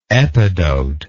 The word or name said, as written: epidotic